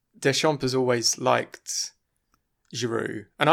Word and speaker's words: Deschamps: Lovely French pronunciation there, Boom Boom, of Deschamps.